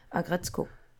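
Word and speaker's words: Aggretsuko: Agretzko.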